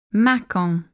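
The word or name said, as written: Mâcon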